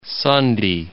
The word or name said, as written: Sunday